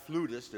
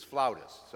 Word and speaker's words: flautist: My musician at Zion used to always remind me, it's not flutist, it's flautist.